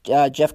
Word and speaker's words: Geoff: Jeff Collins, one of the coolest names. Jeff, how it's spelled, G-E-O-F-F.